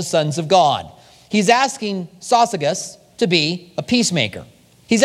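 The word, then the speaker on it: Syzygus: So, companion is a name, and the name is Sosigus. I remember it because it's like sausages with a hard G. Sosigus.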